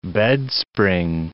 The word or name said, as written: bedspring